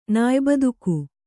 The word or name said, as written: ನಾಯ್ಬದುಕು